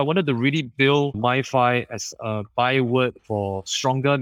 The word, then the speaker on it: MiFi: I eventually settled on MiFi, which rhymes with Wi-Fi for a reason,